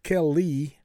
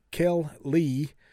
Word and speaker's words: keli: According to the Strong's Concordance, the Hebrew word for weapon is I struggle with some of these pronunciations, but it is keli, like kel-li, which means article, vessel, implement, utensil, yoke.